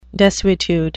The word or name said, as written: desuetude